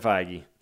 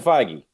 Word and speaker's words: Feige: Kevin Feige. – Kevin Feige.